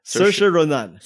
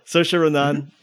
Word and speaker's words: Saoirse: And for this episode, we are going to be talking about Lady Bird, starring based on what she said, it's like it rhymes with inertia. So, Saoirse Ronan. – Saoirse Ronan.